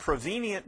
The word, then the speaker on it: prevenient: It's called prevenient grace. Prevenient grace. Prevenient grace, spell it for you, P-R-E-V-E-N-I-E-N-T. P-R-E-V-E-N-I-E-N-T.